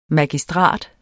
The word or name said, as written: MAGISTRAT